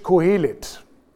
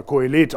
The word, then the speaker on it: Kohelet: Das Buch Prediger, auf Hebräisch Kohelet oder Kohelet auf Deutsch ausgesprochen.